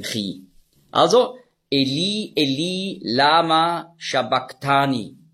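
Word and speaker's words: sabachthani: Und das CH im Griechischen, das ist der ein Buchstabe, das Chi, das hat man ausgesprochen so wie ein C hinten im Gaumen. K. Also nicht Shabach, sondern Shabak.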